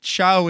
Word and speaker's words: ciao: And I was like, ciao is Italian, Dad. C-I-A-O. Well, that is not how you pronounce that word. That does not look like ciao.